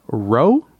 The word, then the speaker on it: Reau: So now that that's out of the way, I'm going to introduce our guest on the other side of the mic here, Justin Rowe, right? Rowe? – Yeah, Rowe. – But it's spelled, how's it spelled? – R-E-A-U.